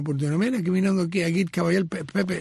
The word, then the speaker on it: Agit Kabayel: Creo que es Agit Kabayel. – Sí. – Agit Kabayel. – Muy bien.